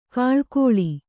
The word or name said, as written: ಘಳಘಳ